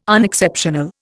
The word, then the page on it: unexceptional: Indian